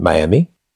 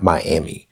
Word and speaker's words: Miami: Ahora estamos en Miami. No, it's not Miami. It's Miami, because I is E in Spanish. I means, or I pronounce, or it's pronounced like E in Spanish. So, Miami.